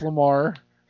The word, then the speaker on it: Lemire: Jeff Lemire. I can't remember how to pronounce Lemire.